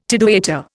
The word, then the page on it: tidewaiter: Indian